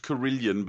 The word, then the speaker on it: carillon: I believe it is pronounced Carillion, but it is spelt Carillon.